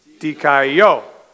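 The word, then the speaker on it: dikaio (δικαιόω): It's one, two, three, dikaio. The accent's on the third syllable.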